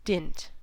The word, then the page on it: dint: American English